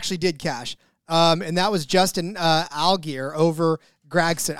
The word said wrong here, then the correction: Allgaier: I always say his name wrong. Allgaier. Allgaier. Come on, man.